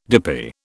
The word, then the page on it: dippy: British English